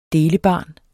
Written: delebarn

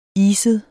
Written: iset

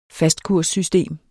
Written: fastkurssystem

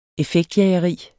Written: effektjageri

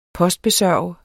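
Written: POSTBESØRGEDE

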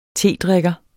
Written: tedrikker

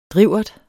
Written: drivert

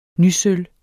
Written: nysølv

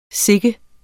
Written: sikken

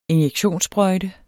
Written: injektionssprøjte

